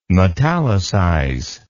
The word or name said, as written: metallicize